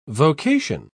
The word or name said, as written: vocation